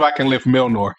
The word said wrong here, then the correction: Mjolnir: You know, Nordic J's are like Y's, I guess. So it's Mjolnir. – Mjolnir.